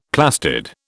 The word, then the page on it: plastid: British English